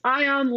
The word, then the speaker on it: Ion: It's Ion like the, you know, the charged atom.